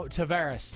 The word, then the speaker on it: Tavares: How do you say this? – Tierra Verde? – Nope. – No, Tavares. – Tavares, Florida.